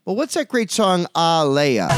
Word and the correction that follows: Aaliyah: It's pronounced Aaliyah, and the song was called More Than a Woman.